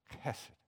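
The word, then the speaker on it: Hesed (חֶסֶד): It's the word hesed. You have to say it like you're getting something out of your throat, okay? Hesed.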